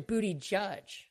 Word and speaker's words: Buttigieg: If you think Buttigieg is going to, it's actually Buttigieg. You know, like the mnemonic for how to say his name. I never get it right, but it should be Buttigieg.